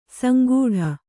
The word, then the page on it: ಸಂಗೂಢ: sangūḍha